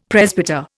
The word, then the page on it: presbyter: Indian